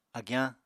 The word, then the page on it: agyañ: Apatani